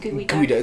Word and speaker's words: geoduck: It's spelled geoduck. – Yeah. – It's pronounced gooey duck.